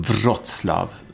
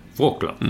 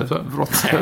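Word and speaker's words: Wrocław: Och vi ska fixa en gäst som jag hoppas kan vara med oss från Wroclaw, som man säger. – Uttalas det så? – Mm. Vad trodde du det var? – Wroclaw. – Mm. Ja, men det är det inte. Wroclaw.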